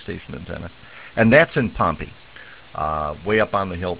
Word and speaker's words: Pompey: And I have a slight advantage in that name, because there is a town just south of here, which is spelled Pompeii, P-O-M-P-E-Y, but everybody calls Pompey around here.